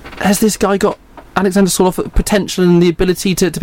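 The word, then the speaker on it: Sørloth: Sorloth, is that how you say his name? – Yeah.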